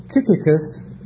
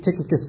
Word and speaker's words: Tychicus: And Paul had a very faithful brother, Tychicus. I think there are approximately 10 different ways of pronouncing that dear brother's name, but I'll say Tychicus.